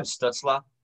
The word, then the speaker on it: Stützle: Well, you know, you might have to pronounce his name right if you want him to win the rookie of the year, because it's Tim Stützle.